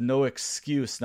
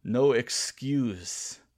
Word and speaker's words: No Exqze: No Exqze. Now that is spelled E X Q Z E. No Exqze.